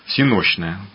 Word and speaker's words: всенощное: Да, действительно, древние христиане, да и мы с вами, знаем такое богослужение, как всеночное бдение, которое по-славянски звучит как всенощное. Правильно было бы ударение ставить. Ну, вот у нас в обиходе уже так принято всенощное.